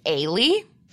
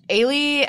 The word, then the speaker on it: Ailee: Also in 2010, here's another, I think, like, big one that you gotta talk about, and this is Aile. – Mm-hmm. – That's spelled A-I-L-E-E.